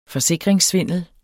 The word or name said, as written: forsikringssvindel